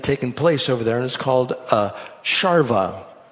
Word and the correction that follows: Sharav: or excuse me, it's not a Sharva, it's a Sharav. That's how they pronounce it, I guess. It's S-H-A-R-A-V.